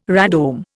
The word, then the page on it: radome: Indian